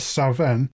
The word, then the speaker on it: Samhain: Come Samhain in Scotland, where I believe it's pronounced more Savin, you have offerings left out Halloween time for the country's fairy cats.